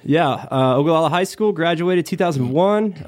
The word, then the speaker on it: Ogallala: Born and raised in Nebraska, 21 years, little town called Ogallala. – What? – Ogallala. – Got it. – So we can spell it O-G-A-L-L-A-L-A.